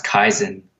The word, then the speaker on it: kaizen: You know, I think it was a couple years ago I learned the term, I think it's pronounced kaizen, which is like a Japanese word for always improving,